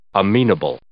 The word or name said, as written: amenable